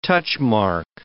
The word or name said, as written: touchmark